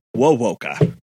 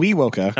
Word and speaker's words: Wewoka: And it's Wowoka. Ah, yes. Not, ah. not Wewoka. Not Weewoka, which is how it's spelled.